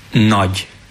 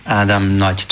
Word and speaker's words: Nagy: So this G and Y, it's pronounced together, G. So it should be pronounced as Nagy. Adam Nagy. Adam Nagy.